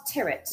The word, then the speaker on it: Tirrett: it rhymes with spirit, so it's Charles Tirrett. – Yeah, That's how you say it.